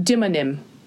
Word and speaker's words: demonym: No, demonym. It's spelled like demon M, like D-E-M-O.